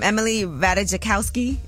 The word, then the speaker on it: Ratajkowski: Help me with her name. Emily Ratajkowski. Is that how you say it? That's perfect. Yep.